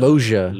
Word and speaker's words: Loggia: How do you say it? I always mix that up. Loggia.